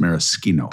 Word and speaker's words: maraschino: And by the way, maraschino liqueur is the way you say maraschino liqueur. It's maraschino.